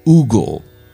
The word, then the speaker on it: Hugo: There's a beautiful poetic rendition by the French poet Victor, I have to now say it properly, Hugo.